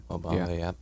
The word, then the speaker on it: Mo Bamba: Mo Bamba, yeah.